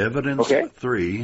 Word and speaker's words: EVID3NC3: It's by Evidence 3, and the reason that I pronounce his name like that, it's spelled EVID3NC3. So look up Evidence 3, and it's Why I'm No Longer a Christian.